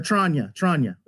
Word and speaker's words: Tranya: Or Tranya. That's how you pronounce it.